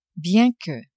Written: bien que

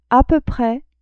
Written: a peu pres

